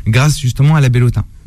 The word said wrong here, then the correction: bellota: La bellota, c'est l'alimentation.